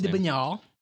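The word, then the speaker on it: de Bernières: I don't know how to pronounce his last name. – Louis de Bernier.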